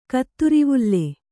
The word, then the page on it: ಕತ್ತುರಿವುಲ್ಲೆ: kaatturivulle